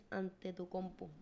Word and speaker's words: Antetokounmpo: Hay una película en Disney Plus de la historia de Giannis Antetokounmpo.